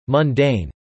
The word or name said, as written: mundane